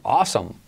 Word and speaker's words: awesome: Con esa vocal awesome. Como asómate.